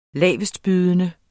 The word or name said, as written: lavestbydende